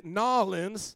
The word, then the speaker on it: New Orleans: Learned to preach in New Orleans, as the locals say, Nawlins.